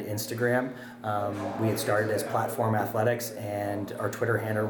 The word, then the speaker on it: PLT 4M: It's bizarre, the different combinations we get, but it's PLT 4M, and it's pronounced Platform.